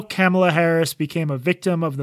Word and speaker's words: Kamala: Okay, but Katie, while you were talking, I literally pulled up the pronunciation guide. Hold on. It says Kamala Harris. – Which is right. You said Kamala.